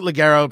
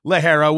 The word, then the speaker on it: ligero: You're talking about uh, you know, you pronounce it Ligero, pronounce it Lajero. I believe it is pronounced Lajero, which is light in, in Spanish.